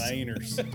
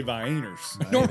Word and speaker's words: Vienna: No, it's got to have the long I on the front. – Vienna. – Vienna. – How do normal people say it? – Vienners. Normal people say Vienners.